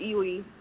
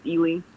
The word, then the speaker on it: Ely: it's Ely. It's pretty simple, but it's kind of hard if you don't know how to say it, but it's just Ely.